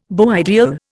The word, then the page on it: beau ideal: Indian